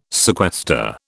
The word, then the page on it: sequester: British English